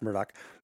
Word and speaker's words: Murdaugh: It's Murdoch Podcast, Murdaugh, even though it's pronounced Murdoch.